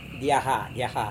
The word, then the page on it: diaha: Serewen